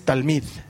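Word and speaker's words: talmid (תלמיד): En hebreo es talmid, es mucho más fácil.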